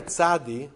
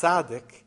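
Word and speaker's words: tzadi (צדי): The Hebrew letter tzadi, or sometimes pronounced tzadik, is written in the Sefer Torah as a nun with a yud on top of it.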